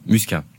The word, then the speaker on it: Muscat: Alors aujourd'hui, Joseph Muscat, ou Muscaté, comment prononcez-vous? – Muscat. – Muscat.